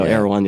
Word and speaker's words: Erewhon: Is that how you say it? Erewhon? – I believe so. Erewhon. Yeah.